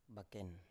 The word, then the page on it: baken: Biak